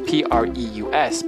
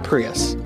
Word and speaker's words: Preus: Her name is spelled, her last name is Preus, but it's pronounced Preus. – Not Prius. – Not Prius. – Not like the car.